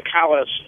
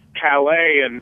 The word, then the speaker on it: Calais: I think I know why Calais is pronounced Calais and not Calais. – Okay. – And it's because at the time that the French named the place, S's were still being pronounced at the ends of most words in French. So the French would have said it's something along the lines of Calais or Calais. And so that's how you end up with Calais or Calais in English.